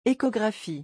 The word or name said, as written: echographie